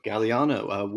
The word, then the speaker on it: Gagliano: But that guest is Rico Gagliano.